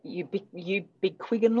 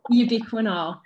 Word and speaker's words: ubiquinol: So tell me a little bit more about the ubiquinol, or how did you pronounce it? – Ubiquinol. – Yeah, ubiquinol, that's it.